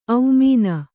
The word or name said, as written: ಔಮೀನ